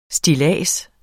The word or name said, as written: stillads